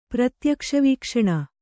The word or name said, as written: ಪ್ರತ್ಯಕ್ಷವೀಕ್ಷಣ